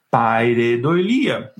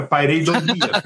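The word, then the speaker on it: pareidolia: Paredolia. Nossa, eu falei errado agora. Paredolia.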